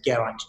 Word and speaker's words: Geraint: So just my username is Geraint John, unpronounceable Welsh name, GERAINT John.